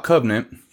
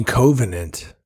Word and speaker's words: Covenant: My favorite thing about Covenant was Fassbender. – You mean Covenant? – Covenant.